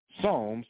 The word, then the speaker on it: Psalms: With that being said, let's go to Psalms, P-S-A-L-M. That's how you spell Psalm, okay? The P is silent. P-S-A-L-M. Psalms, chapter 147.